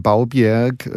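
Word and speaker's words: Bovbjerg: Und irgendwann war ich über die Weihnachtsferien, über Silvester in Baubjerg, so wird es ordentlich ausgesprochen, habe ich mir sagen lassen, an der dänischen Nordseeküste.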